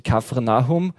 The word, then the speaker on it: Kapernaum: Und er verließ Nazareth und kam und wohnte in Kapernaum. Das ist irgendein kleines Fischerdorf im Norden von Galiläa. Richtig ausgesprochen wahrscheinlich eher so wie Kafarnaum, also das Dorf Nahums.